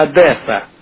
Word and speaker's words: Одесса: Одессу, Саня, Одесса. – Что? – Вы Одесса неправильно произносите. – Одесса, хуесса, блядь. – Одесса, там не Э, а Е.